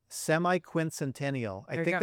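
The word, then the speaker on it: semiquincentennial: how they're celebrating the semi-quincentennial.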